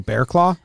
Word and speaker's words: Barraclough: I was just going to say, we've been excited that it's been called Bearclaw because that is the actual pronunciation. But if you saw his name on written down, it looks like Bearclaw.